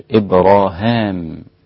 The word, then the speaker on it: إبراهام: إن إبراهيم، إبراهيم قرأها ابن عامر إبراهام في سورة البقرة،